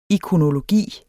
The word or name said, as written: ikonologi